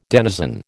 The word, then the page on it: denizen: American English